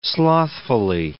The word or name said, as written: slothfully